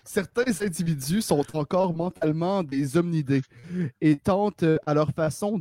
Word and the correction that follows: hominidés: en fait j'ai mal prononcé, c'est des hominidés.